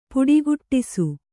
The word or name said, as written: ಪುಡಿಗುಟ್ಟಿಸು